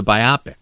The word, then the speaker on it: biopic: Because I still remember Doug taking Dean Richards to task for mispronouncing the word biopic. – Yes. – It's a biopic. – Right. – Which sounds like an operation on your eyes.